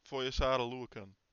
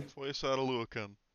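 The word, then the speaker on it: Foyesade Oluokun: Foyesata Luakun.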